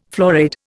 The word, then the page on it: florid: Indian